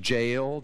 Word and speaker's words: Jael: It rhymes. Jael took a nail.